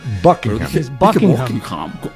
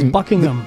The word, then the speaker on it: Buckingham: Buckingham. – Eso sí. Y de hecho, el H medio que se es medio silente. Buckingham. Buckingham. – Más o menos. – No se pronuncia mucho.